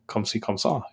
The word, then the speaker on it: comme ci, comme ça: Nice pronunciation on that one, though.